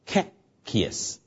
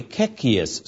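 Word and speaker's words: Coccejus: 最后那位 Kloppenburg 可以说是 Kekius 的先锋。那这么多的名字呢，你记得一个就够了，就是刚才最后那个。那这个拼法是很奇怪的哈，因为他是荷兰人嘛，这个荷兰文呢、有的时候有这个 J 啊 ,C-O-C-C-E-J-U-S, 我们一般英文呢是这样发音的 ,Kekius, 我们来试试看 ,Kekius,Kekius,Kekius。很多时候人们以为 Kekius 是圣约神学之父，事实上是错误的。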